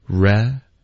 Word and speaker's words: represent: 那实际上呢，我们会把这个一的音弱读成一个就够了，所以我们就说 represent 或者 representative。